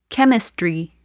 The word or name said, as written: chemistry